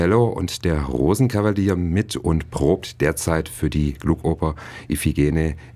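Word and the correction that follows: Iphigénie: en Tauride, wenn ich das jetzt richtig ausgesprochen habe. Iphigen? – Iphigenie. – Iphigenie, okay.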